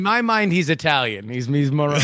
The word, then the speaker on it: Moroni: It's Moroni, technically, but it's just funny to hear other people say it in a different way.